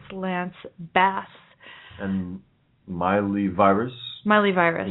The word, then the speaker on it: Bass: Lance's last name is pronounced like the name of the fish. Bass. Bass. – Okay. – Instead of bass.